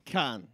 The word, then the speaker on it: Cannes: Cannes. We don't pronounce the S, darling. – Cannes.